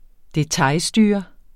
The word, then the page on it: detailstyre: [ deˈtɑjl- ]; [ deˈtɑj- ]